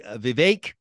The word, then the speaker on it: Vivek: So look, we're going to talk about the Haley launch, the Vivek rhymes with cake, Ramaswamy launch, where things are, is Sununu in, out, etc.